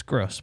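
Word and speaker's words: Groß: It's Gross, but it's spelled with a weird-shaped B.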